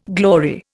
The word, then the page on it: glory: Indian